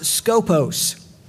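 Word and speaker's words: skopos (σκοπός): The word in the Greek, the Septuagint, which is the Greek translation of the Old Testament Hebrew, is pronounced skopos. Our word scope comes from that word.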